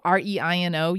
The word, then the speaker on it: Reino: this time to Reino Aventura in Mexico City, Mexico. R E I N O. You speak Spanish. How, how do you oh, pronounce Oh, fluently. Reino. – Reino.